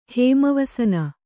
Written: ಹೇಮವಸನ

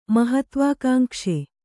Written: ಮಹತ್ವಾಕಾಂಕ್ಷಿ

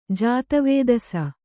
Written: ಜಾತವೇದಸ

